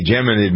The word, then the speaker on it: Geminid: The is it Geminid? I think that's how Geminid. – You pronounce that.